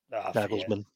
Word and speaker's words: Nagelsmann: Nagelsmann. – Hagelsmann, I always mispronounce it, man.